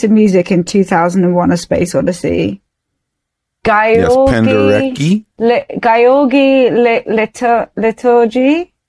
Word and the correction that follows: Ligeti: How do you say it? – Yes, exactly. – Is that how you say it? – We're gonna go with that. – Gyorgy Ligeti. – Yes, Ligeti.